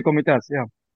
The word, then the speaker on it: Komitas: So Armenia has such a rich musical heritage, and you've performed works by notable Armenian composers like Khachaturian and Komitas, right? Am I pronouncing this? Yeah. – Absolutely, Komitas, yeah.